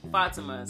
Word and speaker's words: Fatima: Fatima.